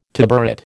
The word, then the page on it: tabouret: American English